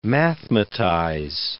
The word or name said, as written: mathematize